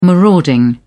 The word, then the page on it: marauding: British English